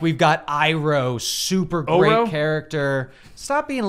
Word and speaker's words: Iroh: You're making it sound like the H is silent. It's Iroh.